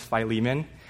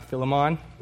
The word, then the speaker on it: Philemon: It's pronounced Philemon, not Philemon.